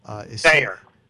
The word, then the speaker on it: Feuer: Fayer? – Fayer. F-E-U-E-R, Mike Fayer.